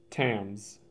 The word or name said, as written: Tamms